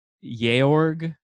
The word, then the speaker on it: Georg: I felt like I was partially listening to Notes on a Burning Age, and I would never have read one of the main characters' name as Georg, which is apparently how you're supposed to pronounce the George without an E name.